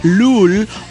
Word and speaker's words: Lol: Me presento, mi nombre es Leslie, pero uah, pueden llamarme Lul o, bueno, esto se escribe con doble O, LOL, LOL o LOLcita.